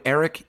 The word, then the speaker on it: Erik: Anyway, you're going to get your first shipment free when you use the special promo code Erik, E-R-I-K.